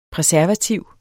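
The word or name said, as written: præservativ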